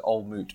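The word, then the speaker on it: Old Mout: Yeah, it says on the glass, it says old moot rhymes with fruit.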